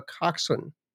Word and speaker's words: coxswain: And of course, on boats, you have a coxswain, which is not even spelled like that, like the word.